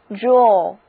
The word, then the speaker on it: drawer: 那我们在它的后面加上名词后缀 er，组成的这个新单词是 draw，哎，仍然念 draw，注意它的发音没有变， draw。